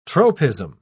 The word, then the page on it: tropism: British English